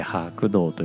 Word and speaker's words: 白銅: 白銅白い銅と書いて白銅と読む